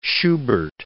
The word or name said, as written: Schubert